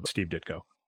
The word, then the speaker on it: Steve Ditko: Obviously, in later interviews, Stan Lee is is very complimentary of Steve Ditko.